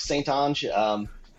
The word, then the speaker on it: St. Onge: It's Mark St. Onge.